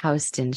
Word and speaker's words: Houston: If we we call it Houston Street. Oh, it's spelled Houston.